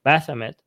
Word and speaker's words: Baphomet: So why would you, this is like, for me, this is a Christian rendering of Baphomet.